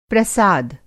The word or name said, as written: प्रसाद